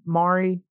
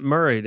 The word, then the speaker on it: Maury: Is it attached to Maury? Maury? – No, it's a they pronounce it Murray down there. – Murray. – So watch out. Yeah, it's spelled Maury, but they call it Murray.